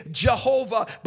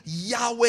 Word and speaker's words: Yahweh: because we in our Greekified way to pronounce it, we would say Jehovah. But really from a Hebrew perspective, the YHVH or YHWH, you know, reading from the right to the left would be Yahweh if we were to put vowels in there according to our language.